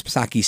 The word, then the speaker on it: Psaki: Asked to confirm one order if it would impact ghost guns, Psaki said, and it's always Psaki, silent P, "I'm just not going to preview more of that from here.